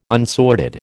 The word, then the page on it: unsorted: American English